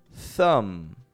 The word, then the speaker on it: thumb: Your thumb is spelt T-H-U-M-B. I don't pronounce that B. That B does not come out.